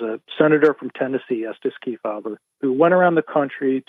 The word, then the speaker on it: Kefauver: You mentioned the 1950s Kefauver. I hope I'm saying that properly. – Kefauver.